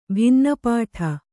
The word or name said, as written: ಭಿನ್ನಪಾಠ